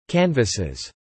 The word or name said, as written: canvases